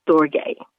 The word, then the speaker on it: storge: The next type of love, it's S-T-O-R-G-E, and it's pronounced storge.